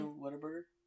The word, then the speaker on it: Whataburger: How do you say Whataburger? Whataburger. – Yeah, he said Whataburger. – He said water. Yeah, when I was there, I used to say Whataburger too.